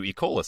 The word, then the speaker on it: Ecola: It's spelled E. cola, E-C-O-L-A, not E. cola, like E. cola virus.